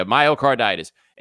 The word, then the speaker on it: myocarditis: I think it's myocarditis. And what it is a viral infection. Yeah, myocarditis.